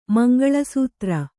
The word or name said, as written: ಮಂಗಳಸೂತ್ರ